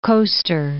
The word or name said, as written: Ivory Coaster